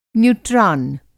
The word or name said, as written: न्यूट्रान